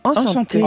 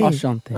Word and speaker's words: Enchanté: Enchanté. – Enchanté.